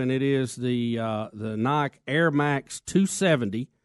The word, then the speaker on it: Nike: Why can't this happen with Nike and the Muslims right now? – Rick, a lot of Muslims are unhappy. They say that Nike, or Nike as it's spelled,